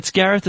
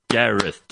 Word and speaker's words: Gareth: It's Gareth. It's not too complicated. Gareth, two syllables.